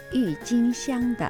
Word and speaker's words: 郁金香: 球根は球根、はい、はい、这是郁金香的球根。